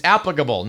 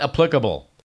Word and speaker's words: applicable: It's applicable, not applicable. and it is correctly prelude, not prelude.